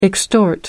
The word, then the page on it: extort: American English